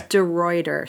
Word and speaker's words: DeRuiter: Did I say that right? – No. – How do we say it? That's okay. – It is okay. I didn't learn to say it till I was 12. Okay. It's DeRuiter.